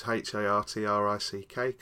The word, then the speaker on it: Hartrick: Yes, at David Hartrick, which is HARTRICK, because no one can ever spell it correctly.